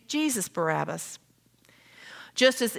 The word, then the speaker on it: Barabbas: So the notorious murderer, insurrectionist, robber's name is pronounced Barabbas, even though through the generations, the common pronunciation has become simply Barabbas.